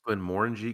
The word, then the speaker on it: Glenmorangie: So I believe it's pronounced Glenmorangie because there's an orange in Glenmorangie.